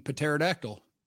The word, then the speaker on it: pterodactyl: I pronounce the P in pterodactyl and there's no reason to, but and you're not supposed to, but they put the letter there.